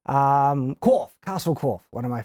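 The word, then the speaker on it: Coch: It's C-O-C-H, and so, but it's in Welsh. And so so the proper pronunciation is like Corfe, and it means red in Welsh, and so it's the Red Castle.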